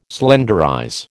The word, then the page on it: slenderize: American English